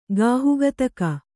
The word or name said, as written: ಗಾಹುಗತಕ